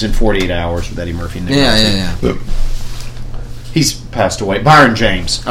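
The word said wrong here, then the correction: Brion James: Brian James. That's his name. Brian. It's spelled. It's like B-R-I-O-N.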